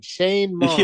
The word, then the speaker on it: Shane Mauss: M-A-U-S-S. I don't know why I called him Sean. Shane Mauss. – Yeah, I was like, what? This is news to me. – I was so focused on pronouncing Mauss correctly that I put the W sound into Shane.